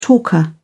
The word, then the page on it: talker: British English